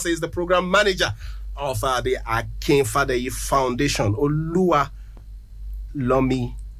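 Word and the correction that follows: Oluwatomi: That's Olua Tomi. – Olua Tomi. – Yes.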